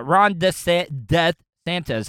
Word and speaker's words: DeSantis: Yeah, Ron DeSantis.